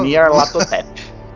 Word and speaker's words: Nyarlathotep: E lá, eles veneravam uma entidade que é o Nyarlathotep. Acho que falei certo. – Pode falar. – Nyarlathotep.